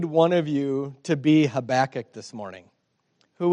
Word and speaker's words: Habakkuk: Let's let's learn how to say his name. Habakkuk. That's right. Everybody say Habakkuk.